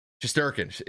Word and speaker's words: Shesterkin: It's pronunciation. Shesterkin. – It's Shesterkin. That's the guy's name.